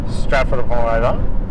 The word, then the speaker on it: Stratford-upon-Avon: We went Stratton-upon-Avon. – Stratton-upon-Avon, or as the locals say it, Stratford-upon-Avon.